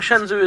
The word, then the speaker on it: Shenzhou: I think Shenzhou is actually how you pronounce it.